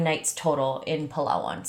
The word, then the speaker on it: Palawan: Oh, so I spent 8 days total in Palawan or Palawan, depends on where you're from, how you pronounce it. Sorry, I'm going to pronounce it Palawan. That's just how I was taught by some locals.